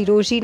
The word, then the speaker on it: Rochie: मेरा नाम है मिर्ची रोशी, नाम की स्पेलिंग है ROCHIE, और जोडियक साइन है, You guessed it, Virgo.